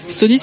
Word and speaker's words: Stony: Stony, ça s'écrit S-T-O-N-Y. Je m'en rends compte en le disant que ça se trouve, vous comprenez Estonie comme le pays.